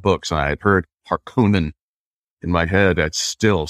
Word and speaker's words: Harkonnen: It is Harkonnen. Even though the David Lynch version 30 years ago got it so wildly wrong, they said Harkonnen,